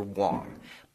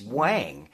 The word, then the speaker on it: Whang: You know, I mean, in the Asian tongue, it's Victor Wang. But in English, it's spelled W-H-A-N-G, so naturally it's Wang. And even when I look at my own name, in my mind, I say Wang.